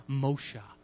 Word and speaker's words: Moshe: In the Hebrew, Moses is pronounced Moshe.